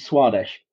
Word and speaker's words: Swadesh: And there's a book called Origin and Diversification of Language by somebody whose surname I couldn't pronounce. yeah. Was it Swedish? Was it Swedish? As if it were you know, Sanskrit or something. Uh, eventually, I learned that it was Swadesh.